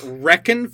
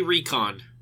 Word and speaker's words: Reckon: He pronounces it like Reckon for some reason, despite that making zero sense and being a shit name. Like, yeah. It should be Recon.